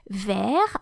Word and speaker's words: vert: Therefore, the masculine form has a final silent T, which you don't hear in the masculine, vert, but you hear in the feminine, verte. So, V-E-R-T or V-E-R-T-E for the feminine is the adjective green.